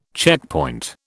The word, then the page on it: checkpoint: British English